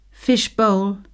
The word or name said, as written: fish bowl